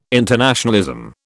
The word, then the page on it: internationalism: British English